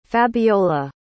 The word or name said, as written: fabiola